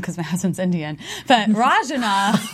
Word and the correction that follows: Ranjana: Ranjana. – Ranjana.